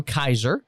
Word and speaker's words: Kizer: We have with us today Andy and Drew Kizer.